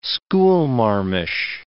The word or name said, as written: schoolmarmish